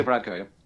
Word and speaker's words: Varadkar: Leo Varadkar, yeah.